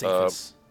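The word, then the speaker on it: Psathas: And then you have the Movement 3 is original, and that is combined with that John Sassis, I'm not really sure how to pronounce it, The View from Olympus. – I think it's Sathis. – Sathis, thank you.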